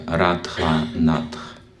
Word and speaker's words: Радха-Натх: правильно произносится Радха-Натх, от слова Радха, Радхарани.